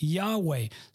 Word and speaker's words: Yahweh: The alternate claim is that the corresponding letters of the Tetragrammaton are YHWH, and with similarly and but different vowels, it is pronounced Yahweh. Similarly, they had to add vowels. They added a couple of extra vowels, those that claim it's YHWH. We pronounce that Yahweh.